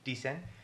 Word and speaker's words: Dicen: Just recently put out a video with Lele Pons for the song Dicen. Did I say that right? Mm-hmm. Dicen.